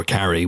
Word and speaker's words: Carrey: Or Carrey, which is the actual way you pronounce his name, but okay.